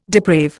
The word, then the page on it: deprave: Indian